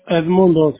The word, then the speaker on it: Edmundo: Edmundo, sí, exacto.